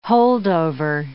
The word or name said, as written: holdover